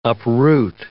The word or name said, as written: uproot